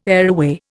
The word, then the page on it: areaway: Indian